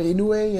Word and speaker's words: Inoue: Inoue? – Inoue, whatever. Inoue, yeah.